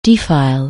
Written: defile

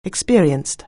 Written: experienced

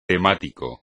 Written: temático

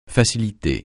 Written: facilité

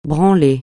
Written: branler

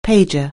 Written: pager

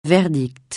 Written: verdict

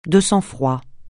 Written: de sang-froid

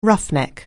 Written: roughneck

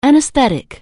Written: anaesthetic

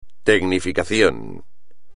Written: tecnificación

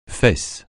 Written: fesse

